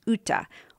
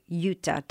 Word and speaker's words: Jutta: We have two characters in this book, and one that isn't mentioned very often in the reviews is Uta, or Jutta, depending on how you want to pronounce J-U-T-T-A. This is his sister.